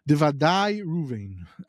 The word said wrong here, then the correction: Dudae Ruvain: I'm for sure saying it wrong. – Dudae Ruvain. Named after the Dudaim.